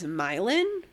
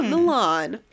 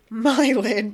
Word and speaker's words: Milan: Like two towns over from where I'm from, Sandusky, is Milan, not Milan, Milan, but it's spelled the same.